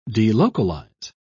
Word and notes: delocalize: British English

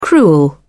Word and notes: cruel: British English

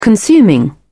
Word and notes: consuming: British English

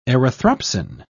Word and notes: erythropsin: British English

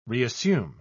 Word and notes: reassume: British English

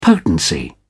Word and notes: potency: British English

